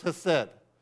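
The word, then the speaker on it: hesed (חֶסֶד): The word is hesed. You can even throw a little breathiness and a little in there and the hesed and sound more authentic.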